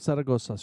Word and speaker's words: Zaragoza: Texas Nightclub on 600 North Zaragoza Street. – It is. – Why do you have to say it like that? – That's how you say it.